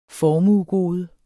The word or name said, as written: formuegode